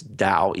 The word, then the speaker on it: Tao: People, the correct Chinese pronunciation is DAO, even though it's spelled T-A-O. But a lot of people say Tao.